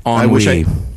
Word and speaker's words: ennui: but now is it Winu? – Ennui. – It's Ennui. It's French. It's Ennui. I pronounce it Ennui.